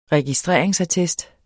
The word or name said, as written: registreringsattest